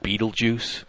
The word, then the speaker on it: Betelgeuse: So if you like Betelgeuse, go for it.